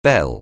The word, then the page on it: bell: anglais